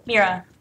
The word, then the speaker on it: Mira: Mira. – Mira. How do I spell that? – M-I-R-A. – M-I-R-A.